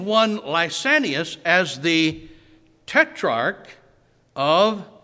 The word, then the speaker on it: Lysanias: In Luke's announcement of Jesus' public ministry in Luke 3 and 1, he mentions one Lysanias, the tetrarch of Abilene. Now I'm pronouncing it like the Greek does.